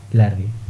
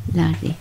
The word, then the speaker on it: Lhardy: Sí, se escribe L-H-A-R-D-Y. – Ah, espera, está escribiendo soufflé de... Oh. Ardi es A-L-D-Y o con R al final. – A-L-H-A-R-D-Y. – A ver, lo miré. Lardi. – Lardi.